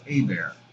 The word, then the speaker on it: Hebert: My last name is actually pronounced Hebert. Oh. Louisiana French.